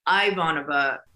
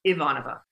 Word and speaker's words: Ivanova: Ivanova is not right from any shape or form, but if you want to call her Ivanova, they can. But it's Ivanova. If you say it properly, Ivanova. Ivanova.